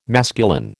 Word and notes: masculine: American English

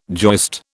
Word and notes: joist: British English

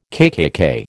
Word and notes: KKK: American English

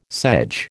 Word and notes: sedge: American English

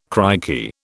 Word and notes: crikey: British English

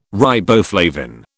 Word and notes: riboflavin: British English

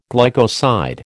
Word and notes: glycoside: American English

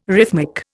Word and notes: rhythmic: Indian

